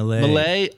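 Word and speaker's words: Milei: Malay.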